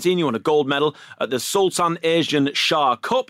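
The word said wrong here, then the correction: Azlan: I think it's Aslan, but Aslan. We're not far off.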